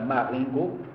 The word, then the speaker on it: Marengo: Porque a, a história acontece em Argel, a principal personagem é o Merceau, cuja mãe morava num numa, numa pequena cidade, que em francês seria pronunciada Marengo, né? a língua francesa é o que oxítona, né? como o prosódia preferencial, então, Marengo, e ele então recebe lá a notícia do, do, do enterro, da morte, né?